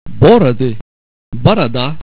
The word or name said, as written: boroda